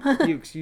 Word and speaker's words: Meux: But you could also do the Mew Home Museum. – Mewks Home. – Mewks. You do pronounce the X. Mewks. – Yeah. So they're not French? – That's not French, no. – M-E-U-X.